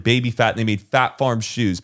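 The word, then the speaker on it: Phat Farm: P-H-A-T. Fat Farm. Oh. And it had that P with like the sound.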